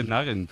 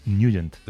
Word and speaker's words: Nugent: De Nagent. – Pero la pronunciación correcta es Nugent.